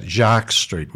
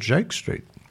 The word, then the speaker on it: Jake Street: Well, it's in France they would pronounce that Jacques Street, wouldn't they? J-A-Q-U-E-S, Jacques Street. But I believe here locally it's called Jake Street.